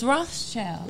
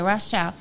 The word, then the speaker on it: Rothschild: Y'all know who was Rothschild family owned the Reserve? – Mm-hmm. – Am I saying it right? – Mm-mm. – It's Rothschild. – Yeah. – Okay, Rothschild. Okay, so you know that the United States is in like $1 trillion.